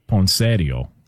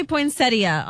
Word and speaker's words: poinsettia: Well, the in-laws would call it poinsettio. – Oh, gosh. – Okay. What up? You know what I'm saying? – Oh, God. – Can we get the proper pronunciation on this? – It does say poinsettia online.